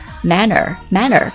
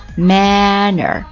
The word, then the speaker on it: manor: 第一个单词是名词，表示庄园领地，英文是 manor，跟那个礼貌那个单词读音是一模一样的 manor。